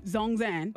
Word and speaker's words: Zhong: Zongs and online, if I said it right. Zong is Z-H-O-N-G and. – Zongzan? – Zongzan.